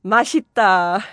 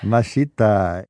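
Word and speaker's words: Maşitta (맛있다): Maşitta. – Maşitta.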